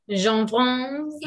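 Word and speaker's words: Cianfrance: bland annat honom och Derek Jean-France, eller Jean-France, kan han heta så? Jag vet inte, uttalar man det? – Jean-France.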